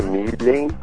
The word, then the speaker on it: Milhem: pronúncia correta é Milen Cortais, é isso? Com um H é, Meu nome é Milen Avanti, tem o italiano no meio e o Cortais no final.